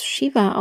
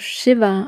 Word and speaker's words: Shiva: Und Shiva, oft wird es Shiva ausgesprochen, aber hier ist nur ein kurzes i drin, also lieber Shiva aussprechen. Und bitte auch kein Sch, sondern ein leichteres Sch und auch kein Ch.